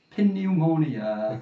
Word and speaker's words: pneumonia: What's the point in having that P at the start of pneumonia? – Yeah.